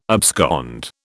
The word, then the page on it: abscond: British English